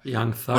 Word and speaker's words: Young Thug: wie bei Young Zack und äh, Sack, keine Ahnung, wie es ausgesprochen wird, Dicker. – Young Thug. Und ja, Bruder, du weißt doch, meine Aussprache, schlimm.